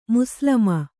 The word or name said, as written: ಮುಸ್ಲಮ